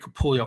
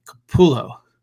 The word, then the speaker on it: Capullo: And we have Greg Capullo. A couple strong matches. I don't know why I always mispronounce Greg's name. Capullo. There we are.